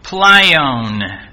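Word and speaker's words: pleion (πλείων): In Greek, it's pronounced pleion. Pleion, spelled P-L-E-I-O-N.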